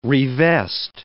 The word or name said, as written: revest